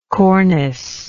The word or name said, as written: corniced